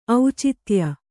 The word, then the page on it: ಔಚಿತ್ಯ: aucitya